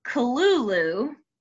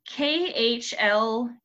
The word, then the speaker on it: Cthulhu: So, the last one on here, it says Cthulhu is pronounced Kalulu because we've all wondered it. It's KHLUL-LOO.